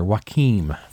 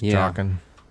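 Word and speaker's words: Joaquin: His brother, Joachim Phoenix. – It's pronounced Joachim.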